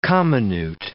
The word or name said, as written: comminute